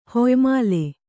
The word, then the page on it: ಹೊಯ್ಮಾಲಿ: hoymāli